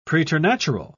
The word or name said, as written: preternatural